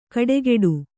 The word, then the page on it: ಕಡೆಗೆಡು: kaḍegeḍu